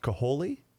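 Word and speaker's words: Kaohly: It's K-A-O-H-L-Y. I believe it's pronounced Kaholi. – Yeah. – Kaholi, is that right? Mm-hmm. And the last name is H-E-R, her.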